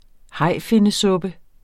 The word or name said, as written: hajfinnesuppe